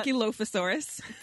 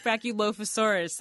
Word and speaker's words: Brachylophosaurus: Now, you use the bones for this research of a kind of Brachylophosaurus. I think I'm saying that right. Can you tell us about Brachylophosaurus? – Brachylophosaurus. There we go.